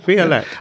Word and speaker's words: violette: Have I said that correctly? – Yeah, violette.